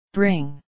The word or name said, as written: bring